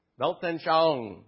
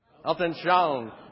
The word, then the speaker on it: Weltanschauung: Weltanschauung. Let's say that together. Weltanschauung.